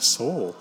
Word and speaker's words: SOL: serialized object ledger, S-O-L, pronounced soul,